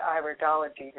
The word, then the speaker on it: Iridology: And I give an introduction to lymphatizing as part of all my iridology readings. And yes, you did pronounce it correctly.